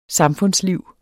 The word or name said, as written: samfundsliv